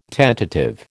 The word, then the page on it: tentative: American English